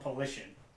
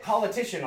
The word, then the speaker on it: Politian: But no one knew how to pronounce it correctly. – Did they call it Politico? – They called it Politician a lot. – Politician. – And I was like, you're adding, like, an extra syllable and three extra letters in there.